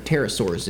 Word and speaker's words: Pterosaurs: Well, one popular theory is that they are pterodactyls, or petrosaurs. Pterosaurs, if you're not supposed to pronounce the P there.